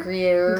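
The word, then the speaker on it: Gruyère: How do you say it? Gruyere? – Gruyere.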